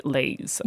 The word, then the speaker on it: Lise: And you're like, rhymes with fleas. – Mm. I'm happy with that because that way people can pronounce it. If they say fleas, they can... say Lise.